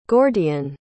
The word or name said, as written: gordian